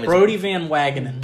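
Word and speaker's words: Brodie Van Wagenen: Brody Van Wagenen.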